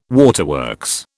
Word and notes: waterworks: British English

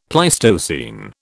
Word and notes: Pleistocene: British English

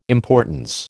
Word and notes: importance: American English

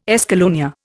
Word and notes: escallonia: Indian

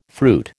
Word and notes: fruit: American English

